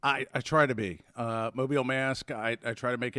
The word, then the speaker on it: Mobile: It happened, and I'm going to pronounce this right, in Mobile. – Correct. – Did I do that one right? – You got it.